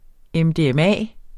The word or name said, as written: mdma